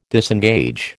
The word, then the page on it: disengage: American English